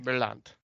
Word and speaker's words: Merlant: Her name is Naomi Merchlin, I think is how you say that. Or Nomai. I think it's a Spanish name, maybe. – No, I think she's French. – Oh, okay. – Naomi Merlant.